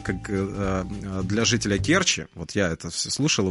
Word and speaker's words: Керчи: Если вы заглянете в словарь русского языка, ну вот вы увидите там, и я надеюсь, наконец-то запомните, что когда а, говорится слово Керчи, то ударение ставится именно на первый слог. Это и есть единственная возможная литературная норма. Множество жителей Керчи говорит Керчи, но это разговорная норма.